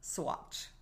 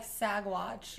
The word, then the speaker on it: Saguache: S-A-G-U-A-C-H-E, and it's pronounced Swatch. – That's weird. I was gonna go like Sagwatch.